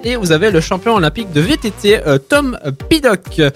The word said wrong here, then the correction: Pidcock: Mon très cher Hachi. De quoi Pidcock. Excuse-moi, les prononciations, ça va arriver.